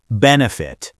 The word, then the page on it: benefit: American English